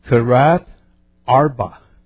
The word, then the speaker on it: Kiriath Arba: And they say it's the Kiriath Arba. Kiriath Arba. I say, where's the TH? Where's the H sound? They don't pronounce the H, so I don't know.